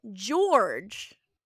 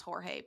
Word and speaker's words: Jorge: We are live on iCarly, and there is a floating bra named George telling a ghost story. – It's pronounced Jorge, please.